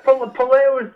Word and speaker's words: Puleo: Yeah, Puleo is the giddy way of saying it.